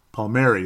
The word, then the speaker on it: Palmieri: Nick Palmieri. Palmieri, that's how you say it.